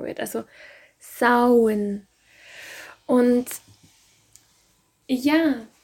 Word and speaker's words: Samhain: Warum hat sie Samhain ausgesprochen und nicht das Wort, das ich da gerade lese? Geschrieben wird Samhain Samhain. Doch das Fest ist ein altes keltisches Fest, das ihren Ursprung ja ungefähr im 8., 9. Jahrhundert hat. So genau kann man das nie sagen bei rituellen, zeremoniellen Festen. Und ja, im Keltisch-Irischen spricht man oft die Worte nicht so aus, wie man sie liest.